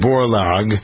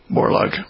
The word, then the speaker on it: Borlaug: All right, so this film is about this fellow Norman Borlaug. Am I pronouncing it right? – That's right, yeah, Borlaug.